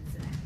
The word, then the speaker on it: Xabak: X-A-B-A-K. – Okay, you're going to spell that again. – X. A-B-A-K. Zabak.